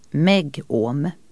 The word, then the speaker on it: megohm: Eller m-omega, som brukar uttalas megohm.